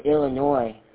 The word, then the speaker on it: Illinois: I'm originally from Illinois, where the S is silent.